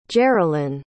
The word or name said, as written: Jerrilyn